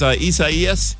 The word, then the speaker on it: Isaias: Am I saying it right? – Yeah, Category 1 hurricane. – No, I mean the name. – Oh. Yes, Isaias.